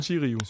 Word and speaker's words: Sotirio: Øh, skal vi ikke bare kalde det nærmest øh, ikke den her ikke offentliggjorte handel med, skal vi simpelthen ikke udtale det, Pietros øh, Citirio. Var det? – Øh, Sotirio. Sotirio. Sotirio. Ja.